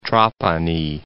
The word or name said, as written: Trapani